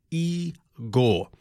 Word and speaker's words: Igor: This is Igor. Pronounced E-Gore."